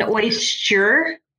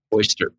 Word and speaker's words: oyster: An oyster? – Oyster. What is it? Oyster? – Oyster. – Oyster. – Did you seriously just... – It doesn't rhyme with moisture? – Oyster does not rhyme with moisture. No.